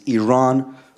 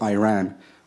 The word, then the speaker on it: Iran: You say you support the Iranian people, but you're pronouncing our country's name wrong. Our country is named Iran, not Iran.